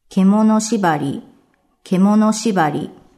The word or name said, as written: Kemono shibari